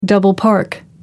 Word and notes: double park: American English

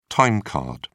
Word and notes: time card: British English

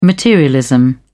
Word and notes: materialism: British English

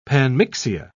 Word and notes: panmixia: British English

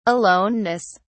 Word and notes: aloneness: British English

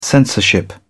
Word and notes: censorship: British English

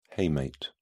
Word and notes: hamate: British English

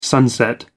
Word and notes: sunset: American English

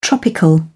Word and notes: tropical: British English

